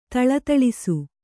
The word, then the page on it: ಥಳಥಳಿಸು: Kannada